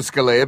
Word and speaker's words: Scalia: Is that the right pronunciation? – Oh, Scalia. It's Scalia, Scalia is technically his name.